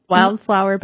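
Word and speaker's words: Wildflour: Wildflower, but spelled like F-L-O-U-R.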